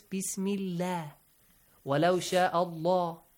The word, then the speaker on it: Allah: The word Allah, sometimes it is pronounced with a heavy sound. Allah.